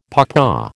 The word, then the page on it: pawpaw: American English